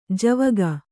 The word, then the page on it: ಝಾವುಕ: jhāvuka